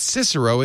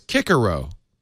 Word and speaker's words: Cicero: But when I was learning Latin, the whole new thing, and this was like 100 years ago, but the whole new thing was, oh, no, C's are hard. So it's not Cicero, it's Cicero, which nobody liked.